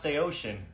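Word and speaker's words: Saosin: Yo, I hate to ruin everyone's night, but this band's name is pronounced Seosin, and I have proof.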